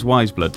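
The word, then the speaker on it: Weyes Blood: Is it pronounced Wiseblood? Weyesblood? – I think we eventually concluded it was Wiseblood, didn't we? We talked about this quite a lot.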